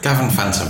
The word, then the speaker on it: Gavan Fantom: Gavan Fantom.